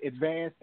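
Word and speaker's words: Edvanzd: My name, I know it's spelled weird, but it's E-D-V-A-N-Z-D, but it's still pronounced Advanced, and that's where you can find it anywhere across the board.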